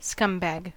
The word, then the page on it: scumbag: American English